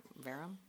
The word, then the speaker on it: verum: Verum? How do you say it? – Cinnamomum verum, V-E-R-U-M.